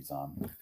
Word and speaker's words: Zahn: You know, you could take those novels that Timothy Zahn did, because Thrawn was very much a creation of Timothy Zahn, the author. – Uh-huh. And Timothy Zahn.